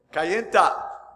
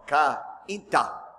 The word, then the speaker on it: Cainta: I was at the church in Cainta, amen. The first time I pronounced that, I said Cainta, because that's how you say it in English. It would be a diphthong, Cainta. No, it's Cainta, amen.